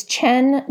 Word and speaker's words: Qian: It's Chen Julie Wang, and Chen is spelled Q-I-A-N, and the book was called Beautiful Country.